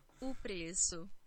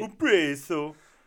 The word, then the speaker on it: O preço: O preço. – There you go. Yeah. O preço. – The C with a little swirl underneath it. That's yes. It's the soft C sound.